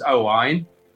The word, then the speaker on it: Owain: My parents are Welsh, so my real name's Owain. So it's spelled O-W-A-I-N.